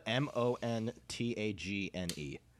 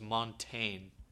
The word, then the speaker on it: Montagne: But I always thought his name was Montaigne. – Uh, it's Montagne.